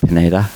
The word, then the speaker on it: Pineda: And we also forgot to mention that Arnell, is it Pineda? I pronounced that right. Picking up the pieces in 2020. – Correct.